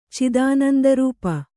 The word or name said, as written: ಚಿದಾನಂದರೂಪ